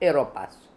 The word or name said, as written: Heropass